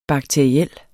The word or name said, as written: bakteriel